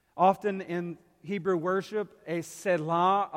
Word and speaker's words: Selah: If you read the Psalms, like Psalm 4, you'll see a little word in the Hebrew pronounced Selah. S-E-L-A-H, Selah.